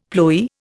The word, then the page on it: ploy: Indian